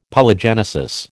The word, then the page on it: Polygenesis: American English